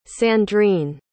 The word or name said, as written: sandrine